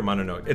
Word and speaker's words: Mononoke: Say did you say Mononoke or Mononoke? It's Mononoke. – Is it Mononoke? I probably messed it up. – Mononoke.